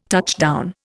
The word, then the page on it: touchdown: Indian